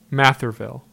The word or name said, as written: Matherville